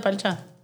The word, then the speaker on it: parcha: And then, mind you, it's spelled parcha with the R in there. But we say, jugo de parcha.